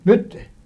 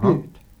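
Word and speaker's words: Butte: Och så stod där Bjut, Bytte. Uttalas Bjut.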